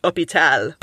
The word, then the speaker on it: hôpital: And hôpital, which in English was borrowed as hospital, was borrowed before the H became silent in French, and also before the S became silent. And so it's now pronounced hôpital, but it's spelled like hôpital, but with the hat on the O.